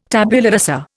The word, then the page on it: tabula rasa: Indian